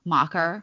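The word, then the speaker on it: macher: It's M-A-C-H-E-R, mocker. Okay. It's like German, Yiddish German.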